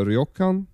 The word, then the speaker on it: ryokan: We had booked a room at Hotel Fukudaya, which is a ryokan. – Mm-hmm. – Is that how you pronounce it? – Yeah.